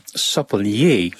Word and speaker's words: sapelier: Dat is het woord sapelier. Ik denk dat ik het zo moet uitspreken, sapelier.